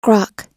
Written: grok

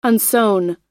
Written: unsown

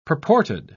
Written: purported

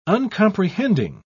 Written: uncomprehending

